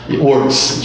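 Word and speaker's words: MathWorks: Eles são representantes da MathWork, tentar pronunciar corretamente, MathWork, né? Works, desculpa. E,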